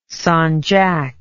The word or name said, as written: sanjak